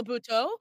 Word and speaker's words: Buteau: Buteau? – Michelle Buteau.